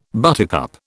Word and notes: buttercup: British English

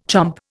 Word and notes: chomp: Indian

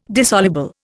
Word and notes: dissoluble: Indian

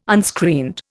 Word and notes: unscreened: Indian

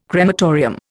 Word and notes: crematorium: Indian

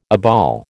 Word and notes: obol: American English

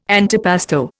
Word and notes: antipasto: Indian

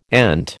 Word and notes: ant: American English

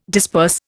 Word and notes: disperse: Indian

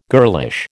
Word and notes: girlish: American English

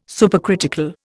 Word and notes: supercritical: Indian

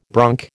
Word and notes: bronc: American English

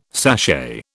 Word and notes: sachet: British English

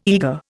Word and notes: eager: Indian

